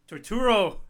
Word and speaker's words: Turturro: John Tortuga? – Torturo. – Oh. – 10 cents. We just said it right.